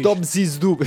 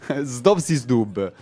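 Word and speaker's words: Zdob și Zdub: Zdobzi Zdub, sì, certo. Zdobzi Zdub.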